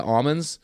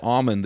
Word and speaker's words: almond: Oh, it's like in California, the almonds, they don't pronounce almond. They pronounce it almond because they tell me they beat the L out of them.